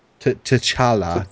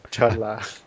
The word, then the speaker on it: T'Challa: T'Challa or Chitala? – T'Challa. – T'Challa. – I was trying to impress y'all with my pronunciation of the name. – Your Wakandian. – I just screwed up.